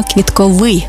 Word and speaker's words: квітковий: Я прямо відчуваю цей квітковий запах. До речі, правильний наголос саме квітковий, бо стосується квітів. А квіткові – це про органи розмноження за допомогою квіток.